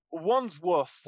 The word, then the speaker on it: Wandsworth: Wandsworth.